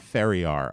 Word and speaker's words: Ferriar: F-E-R-R-I-A-R. – I-A-R? – Yep. – Ferrier.